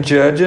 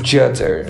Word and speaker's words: judge: Remember, only God can judge us. Mas enfim, Forget eu vou. The haters. – Pois é, mas eu vou furar essa, eh, essa, essa regra e hoje eu quero ser o. Judder.